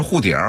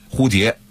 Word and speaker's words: 蝴蝶: 人就让你说啊二胡怎么说啊，可能有的人说二胡就是二胡啊，错了，北京话里的二胡一定是二呼，二呼，北京话里的蝴蝶一定是护蝶，绝对不是说北京话是护蝶啊，蝴蝶一定说护蝶，实际上这个字的这个普通话的正确的标准发音蝴蝶，